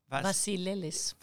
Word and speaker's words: Vassilelis: Now, my guest today is Nikki Vassilelis, and that's pronounced Vassilelis, or how do you pronounce it in Greek? – Vassilelis. – Vassilelis? I got it just about right. Yes. Yeah. It's a Greek name.